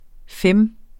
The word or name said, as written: FEM